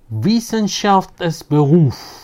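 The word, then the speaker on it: Wissenschaft als Beruf: kitabından Wissenschaft es Beruf diye telaffuz edebiliriz.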